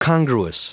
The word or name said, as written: congruous